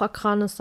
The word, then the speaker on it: Akranes: Ist der Betonungsgipfel woanders? Akranes? – Ich würde auch Akranes sagen.